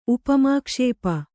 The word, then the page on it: ಉಪಮಾಕ್ಷೇಪ: Kannada